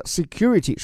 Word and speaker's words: security: 这个 security，首先注意它的发音哈，这个 e 字母它发的不是 a，而是 e 啊。